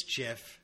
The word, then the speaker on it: Jiff: So his name, by the way, is Jif, and it is spelled J-I-F-F, which J-I-F-F is pronounced Jif. Now, if his name was spelled G-I-F, it would be pronounced GIF. I just wanted to say that to anybody that might be confused. J-I-F or J-I-F-F would be pronounced JIF because there's a J at the beginning. – Mm-hmm. – And, you know, unless your name is George, it G-I-F, that's a hard G right there.